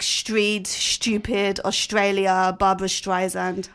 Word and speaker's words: Streisand: I had no idea that you're meant to go Streisand, Barbara Streisand, all my life.